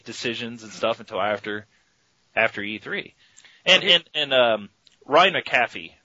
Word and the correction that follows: McCaffrey: Am I saying it right? – Ryan McCaffrey. I think it's McCaffrey. – Okay, thank you.